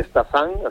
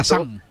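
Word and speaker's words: Stassin: Estamos hablando de un delantero, el nombre de Stasin, así se pronuncia, este jugador belga muy jovencito. – Stasin. – Stasin,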